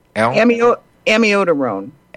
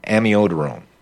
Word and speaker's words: amiodarone: Amiodarone. – Amiodarone.